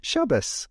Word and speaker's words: Shabbos: I would say it with a T at the end. Most Jews that you meet will say Shabbos, because European Jews tend to pronounce the certain letters as a TS sound, which has sort of evolved to just an S.